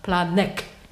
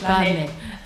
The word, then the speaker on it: Planegg: Sag mal, Planegg, nicht Planegg. Planegg.